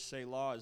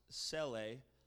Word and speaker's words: Selah: The word we pronounce in the English language, Selah, is actually pronounced Seleh in the original, and it means, its literal definition means to lift up or to exalt, but its use is a bit ambiguous.